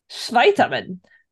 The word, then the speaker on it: Schweiterman: I'm going to say that W is pronounced like a V. Schweiterman.